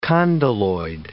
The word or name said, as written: condyloid